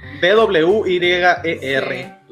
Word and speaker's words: Dwyer: D W Y E R.